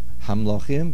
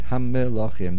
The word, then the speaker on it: hamilochim: Not hamlochim, but hamilochim. There's a dagesh in the mem.